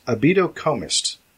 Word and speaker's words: abetocomist: And it is, it's got five syllables, abetocomist. Abetocomist, that is spelled A-B-Y-D-O-C-O, and then M-I-S-T, like mist.